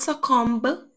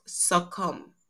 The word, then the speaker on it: succumb: So it's not succumb because there's a B at the end. It's pronounced succumb.